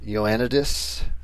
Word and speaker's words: Ioannidis: John Ioannidis. Is that how you say his name? – Oh, yeah. Yeah.